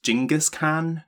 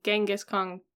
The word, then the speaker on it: Genghis Khan: I believe it's pronounced Genghis Khan nowadays. – I think it's changed from Genghis Khan to Genghis Khan.